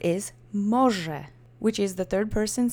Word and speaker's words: może: Let's say może together three times. And same as before, accent is on the first syllable.